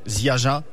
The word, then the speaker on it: Ziaja: Sur la page Facebook, vous pouvez nous trouver aussi à l'adresse Ziaja France, donc ça s'écrit Ziaja France, avec la prononciation française.